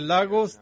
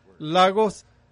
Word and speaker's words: Lagos: Así que estamos muy emocionados de tener a nuestro hermano Deli Adeledon de nuevo esta mañana de Lagos, Nigeria. Ahora, si son hispanoparlantes, dirán esa palabra, Lagos. Dirás, no, es Lagos. Se escribe de la misma manera.